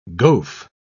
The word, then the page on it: goaf: British English